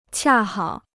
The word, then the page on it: 恰好: qià hǎo